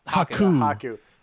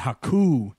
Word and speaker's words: Haku: Haku. – The emphasis on the U and the U at the end. Haku.